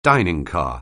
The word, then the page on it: dining car: anglais